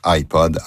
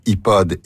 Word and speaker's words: iPod: Les noms de ces trois marques de commerce de la compagnie Apple se prononcent généralement en anglaise, en l'occurrence iPod, iPad et iPhone. Les prononciations francisées iPod, iPad et iPhone sont parfaitement acceptables, mais beaucoup moins courantes.